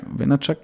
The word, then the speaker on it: Vaynerchuk: Vaynerchuk oder sowas? Vaynerchuk. Vaynerchuk.